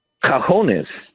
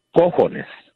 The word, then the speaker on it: cojones: Cajones, C-A-J-A, is drawers. But like I told your screener, for some reason, you know, other people than Mexicans or Hispanics always say cajones, which is not the correct pronunciation. It's cojones.